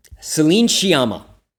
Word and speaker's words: Céline Sciamma: Céline Sciamma.